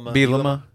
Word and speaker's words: Bielema: Illinois fired Lovie Smith, hired Brent Bielema. I don't know how to say his name. – Bielema. – Yeah, that is how you say it.